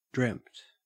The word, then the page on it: dreamt: Australian English